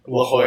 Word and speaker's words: La Jolla: La Jolla.